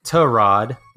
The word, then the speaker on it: Tyrod: But he hasn't been getting critical reps with either Baker Mayfield or Tyrod, which is really weird to say, but apparently that's how you say it.